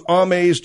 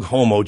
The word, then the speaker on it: Hommes: Vogue Hommes Japan. – Vogue Homo Japan. Yeah. – It ain't Vogue Homo Japan, okay? H-O-M-M-E-S. How do you figure that's homo? – Well, I mean, it means homo.